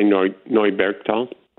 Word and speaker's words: Neubergtal: How would you say it? – I would just say Neubergtal. – Neubergtal? – Yeah. Okay. Or Neubergtal. – Neubergtal. – The anglicized version is Neubergtal.